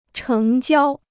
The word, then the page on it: 成交: chéng jiāo